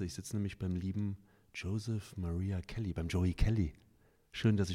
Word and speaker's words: Joseph Maria Kelly: Habe ich deinen Namen richtig ausgesprochen? – Ja, so heiße ich. Joseph Maria Kelly.